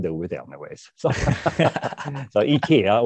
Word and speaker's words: IKEA: Well, in English, it's IKEA. In Swedish, it's IKEA.